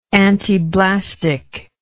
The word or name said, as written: antiblastic